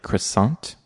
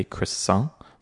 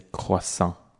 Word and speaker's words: croissant: In the US, people tend to pronounce it like croissant. They'll pronounce the T on the end, which to French speakers sounds very bad, sounds very harsh, because in French, many of the letters at the end of a word, you don't pronounce them. And this is a French word. So I personally would recommend pronouncing it as a croissant, so not pronouncing the T. And if you want to really sound super French, it is a croissant.